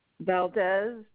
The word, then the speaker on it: Valdez: Karen, K-A-R-E-N. – And your last name? – Valdez, V like Victor, A-L, D like dog, E, Z like zebra.